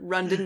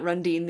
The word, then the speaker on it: Rundine: It is Bert. Bert Rundin, not Rundine. The E is silent.